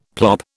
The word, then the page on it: plop: British English